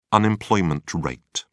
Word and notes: unemployment rate: British English

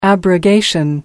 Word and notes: abrogation: American English

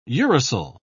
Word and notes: uracil: British English

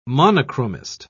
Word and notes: monochromist: British English

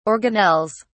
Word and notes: organelles: British English

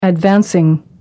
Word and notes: advancing: American English

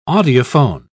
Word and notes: audiophone: British English

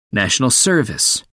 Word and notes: national service: American English

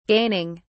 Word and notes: gaining: British English

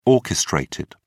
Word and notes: orchestrated: British English

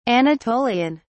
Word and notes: anatolian: British English

